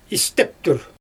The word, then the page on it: isteptür: Xyzyl